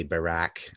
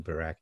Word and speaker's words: Barak: Ehud Barak was also, I know I pronounced that with a T, but their T and K is kind of similar, but we'd say Barak, I guess, kind of like, sounds like Barack Obama, but Ehud Barak.